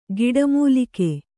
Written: ಗಿಡಮೂಲಿಕೆ

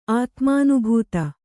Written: ಆತ್ಮಾನುಭೂತ

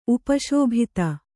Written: ಉಪಶೋಭಿತ

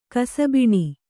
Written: ಕಸಬಿಣಿ

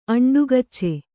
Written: ಅಂಡುಗಚ್ಚೆ

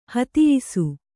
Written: ಹತಿಯಿಸು

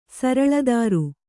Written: ಸರಳದಾರು

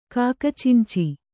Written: ಕಾಕಚಿಂಚಿ

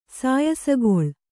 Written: ಸಾಯಸಗೊಳ್

